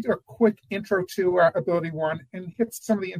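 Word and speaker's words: AbilityOne: GSA, along with 10 other agencies, by statute, has a member of the Ability One Commission.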